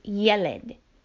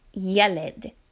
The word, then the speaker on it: yeled: The word for boy is yeled, yeled. Hayeled is the boy. Yeled, hayeled. Remember to put the emphasis on the last syllable.